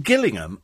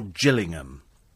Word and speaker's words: Gillingham: Is it Gillingham or Gillingham? Because I heard somebody pronounce it Gillingham. There is a... Oh, there's a Gillingham and a Gillingham. Ah. Oh, right. Gillingham's in Kent and Gillingham's in Surrey.